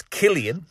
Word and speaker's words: Cillian: Well, my daughter was very adamant that I tell you on air that it's Killian, and that I think she was enjoying being right over you.